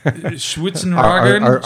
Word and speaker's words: Schwarzenegger: I can't believe you pronounced his name right. – Yeah. Schwarzenegger.